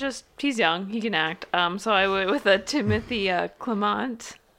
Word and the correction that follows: Chalamet: That's Chalamet.